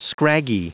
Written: scraggy

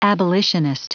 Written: abolitionist

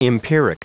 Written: empiric

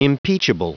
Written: impeachable

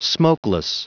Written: smokeless

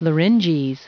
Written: larynges

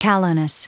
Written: callowness

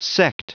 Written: sect

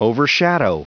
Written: overshadow

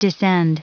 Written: descend

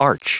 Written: arch

